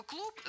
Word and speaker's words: клуб: А слово «клуб» звучит в конце «пэшно», хотя пишется «б» – «бэшно».